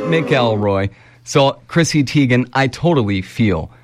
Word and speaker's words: Teigen: The model took to social media and said it's really not Teigen, but Teigen.